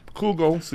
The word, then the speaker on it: Kugel: Como que eu falo seu sobrenome? – Kugel. – Kugel! É melhor não pronunciar em português, porque aí vira Kugel.